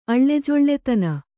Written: ಅಳ್ಳೆಜೊಳ್ಳೆತನ

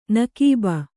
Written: ನಕೀಬ

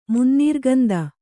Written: ಮುನ್ನೀರ್ಗಂದ

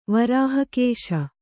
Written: ವರಾಹಕೇಶ

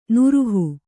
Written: ನಿರೀಹ

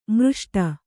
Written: ಮೃಷ್ಟ

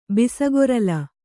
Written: ಬಿಸಗೊರಲ

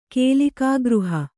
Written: ಕೇಲಿಕಾಗೃಹ

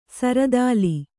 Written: ಸುರತಾಲ

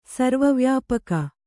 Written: ಸರ್ವವ್ಯಾಪಕ

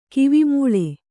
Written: ಕಿವಿಮೂಳಿ

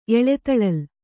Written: ಎಳೆತಳಲ್

